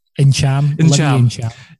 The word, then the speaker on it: Ntcham: Ntcham.